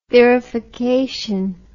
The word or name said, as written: thurification